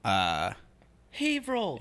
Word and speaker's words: Haverhill: Haverhill. – Yeah, Haverhill, Mass. – And it's spelled Haverhill. – Yeah, it's like Haverhill.